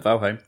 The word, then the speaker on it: Valheim: I couldn't pronounce that one last week, could I? – That's it, Valheim. – Valheim.